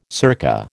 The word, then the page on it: circa: American English